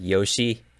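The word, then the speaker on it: yosh (よし): The word yoshi is like a word that it shows affirmation. Like, let's do this. I've got this. And it's pronounced yosh.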